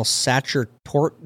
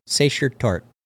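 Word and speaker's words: Sachertorte: I, I, I know I'm saying that wrong, and I'm gonna get messages that are. He said Satcher Tort. looks like a pie. All right, it's a Sacher Satcher Tart. Satcher Tart.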